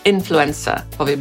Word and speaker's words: influencer: Influencer, powie Brytyjczyk, Amerykanin, influencer. Akcentujemy pierwszą sylabę i słowo to pochodzi od czasownika influence, czyli mieć wpływ na albo wpływać na.